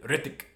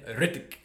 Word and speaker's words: Rittich: But anyways, she pronounced his name as David Rittich. – So that's how you say it. – David Rittich. – That's the official pronunciation.